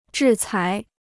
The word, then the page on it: 制裁: zhì cái